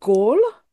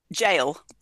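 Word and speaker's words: Gaol: And I'm so going to pronounce this incorrectly, which is Norwich, is it Gaul? – Jail. – Oh, okay. – Sorry. But the J spelling, but yeah, okay.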